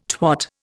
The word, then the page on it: twat: Indian